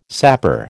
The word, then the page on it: sapper: American English